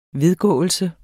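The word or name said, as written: vedgåelse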